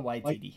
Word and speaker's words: Waititi: Oh man, that was such a funny scene, and it just it's I think because of how do you pronounce his last name? – Taika Waititi.